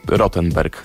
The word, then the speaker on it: Rottenberg: Nie wiem, czemu wcześniej akcentowałem go tak po angielsku. Chłopacy tego nie robią, więc trzymajmy się po prostu Rottenberg, tak z polską wymową.